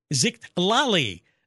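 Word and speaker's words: Xitlali: My mom wanted me to have a unique name, so she named me Zictlali,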